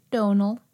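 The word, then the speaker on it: Domhnall: Donal.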